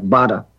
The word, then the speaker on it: Beata: She spells her name B-E-A-T-A. And the Polish pronunciation of that is something like Bada. And her last name is S-Z-Y-D, that Polish L with a strike across it, O.